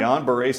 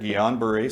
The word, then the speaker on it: Guillain-Barré: Is it Guillain-Barré? – Guillain-Barré syndrome was the first thing they said. Just so y'all know, that's modern-day polio symptoms. – Okay, say it again. – Correct. Guillain-Barré syndrome.